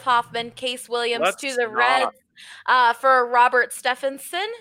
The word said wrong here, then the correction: Stephenson: Did I say that right? – No. – Damn. Stevenson. My brain.